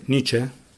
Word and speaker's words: Nietzsche: E, lo pronuncio bene, Nietzsche? Pronunciato bene. Grazie. Leggevo